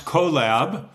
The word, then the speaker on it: QOLab: So the company is pronounced CoLab, and it's Q for quantum, of course, plus collaboration.